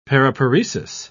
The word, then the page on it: paraparesis: British English